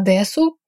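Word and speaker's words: дэс (です): При редукции гласный почти не произносится, хотя вы как бы собираетесь его произнести, но без голоса. То есть «дэщита» будет звучать как «дэщита», а «дэсу» – как «дэс».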